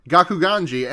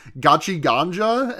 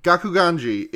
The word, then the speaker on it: Gakuganji: We also, and then, Gojo taunts, Gakuganji, and while listening to the last episode, I realized that I got his name wrong, and I said it the most times I've ever said it in an episode, because I kept calling him Gachi Ganja, and I don't know where I got that from, but it's kind of close.